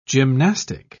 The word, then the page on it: gymnastic: British English